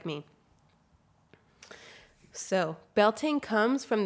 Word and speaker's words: Beltane: And I just want to preface too that it's my understanding that the Irish and like the Gaelic pronunciation is Beltane, and there are quite different ways that people celebrate it.